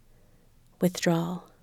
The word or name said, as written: withdrawal